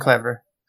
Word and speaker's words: Clever: Ganz schön Clever. – Clever. I know, I always want to say Cleaver, but I know that's not right.